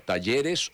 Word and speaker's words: talleres: ¿Cómo se pronuncia? ¿Talleres o talleres? – Nosotros decimos talleres.